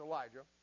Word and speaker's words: Elijah: Now don't worry about the way it's spelled there. That's the difference between coming from the Hebrew to the English and then the Greek to the English. It's Elijah.